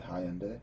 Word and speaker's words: Hyundai: Is it Hyundai? – It is.